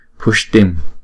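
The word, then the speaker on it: puṣṭim: Chữ S có dấu nạn và chữ T có dấu nạn chúng ta phải đọc là Sh-ta, Pushtim, Pushtim. Trong bài chú là Pushtim, Pushtim, nghĩa là tăng lên.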